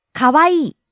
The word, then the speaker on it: かわいい: かわいい。